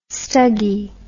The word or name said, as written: stuggy